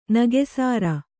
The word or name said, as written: ನಗಸಾರ